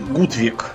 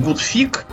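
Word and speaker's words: Gutvik: Но там у них есть такая, такая была кроватка Гутвик. Так. По-шведски. Но по-немецки это читается как Гутфик. И звучит как-то типа хороший перепихон. Такое. Совсем. Да, да. У немцев же вот это вот V, которая не W, а именно V, она как F читается, да.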